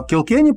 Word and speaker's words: Килкенни: А Килкенни правильное ударение? – Да.